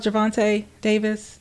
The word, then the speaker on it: Gervonta: I think you sent me this, Diva, about Gervonta Davis, the boxer. Is that the boxer? Did I say that right? Gervonta Davis? – I did. Yes, about the plane crash.